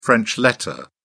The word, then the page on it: french letter: British English